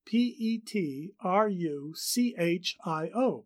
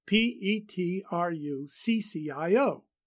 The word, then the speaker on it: Petruchio: Those performers who persist in pronouncing it Petruchio to show off their knowledge of Italian, in which the CH is pronounced like a K, fail to realize that Shakespeare was a step ahead of them.